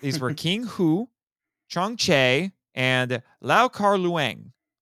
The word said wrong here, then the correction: Leung: I believe it's Leung.